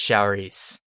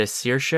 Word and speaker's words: Saoirse: I don't think it's Showrice. – Okay. – I think it's closer to Saoirse or Saoirse.